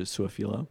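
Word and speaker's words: Su'a-Filo: Oh, Xavier Suafilo.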